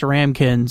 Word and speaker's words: ramekin: Yeah, someone called and said she believes it's pronounced ramekin. So there's an E in the word, but you don't pronounce it.